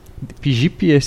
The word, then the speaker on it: Deep Jeep: Deep Jeep se pronuncia D-E, espaço, P-I-J-I-P.